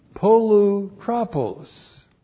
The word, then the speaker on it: Polutropos: Polutropos. P-O-L-U-T-R-O-P, long O-S. Polutropos.